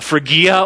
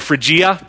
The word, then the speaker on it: Phrygia: Colossae is located in this region called Phrygia or Phrygia, depending how you want to pronounce it.